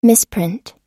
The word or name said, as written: misprint